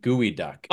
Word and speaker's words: geoduck: It's not a geode or a duck. It's actually it's pronounced gooey duck.